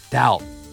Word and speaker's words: doubt: あとまあこのスペルがね、こう書いてあるけど、 B とか発音しないのでね。 doubt。